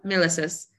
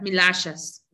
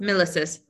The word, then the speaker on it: Milasas: Mi apellido es de Lituania y de hecho se debería pronunciar Milashas. Pero en inglés es milises Mi nombre es Simón milises y estás en el podcast, en el podcast de Elección Cambio Acción.